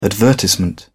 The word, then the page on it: advertisement: British English